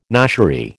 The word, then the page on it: noshery: American English